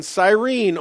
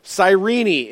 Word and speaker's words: Cyrene: And all three gospel accounts include Simon was born in Cyrene, or Cyrene, it may be pronounced, which would be a modern equivalent to Tripoli in Libya, North Africa.